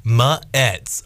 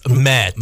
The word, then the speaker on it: Mets: It's like this. Mets. Mets.